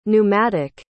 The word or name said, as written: neumatic